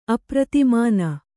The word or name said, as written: ಅಪ್ರತಿಮಾನ